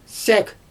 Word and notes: sAk: Xyzyl

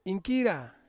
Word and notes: inkira: Ansus

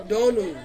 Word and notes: donu: Ansus

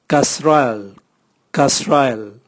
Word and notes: kasrael: Raga

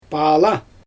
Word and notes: paala: Xyzyl

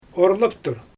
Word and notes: orlaptur: Xyzyl

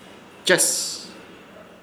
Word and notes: Ches: Ishir Chamacoco

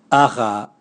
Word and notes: aga: Raga

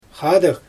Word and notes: xaadɯg: Xyzyl

